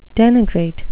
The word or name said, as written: denigrate